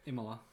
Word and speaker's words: Imola: Imola. – Imola.